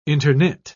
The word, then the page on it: interknit: British English